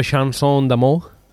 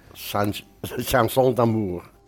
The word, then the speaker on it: chanson d'amour: Jo és que jo francès no sé si ho he pronunciat bé. – Chanson d'amour. – Chanson d'amour. – D'amour. – Ah. – D'amour. – Très bien.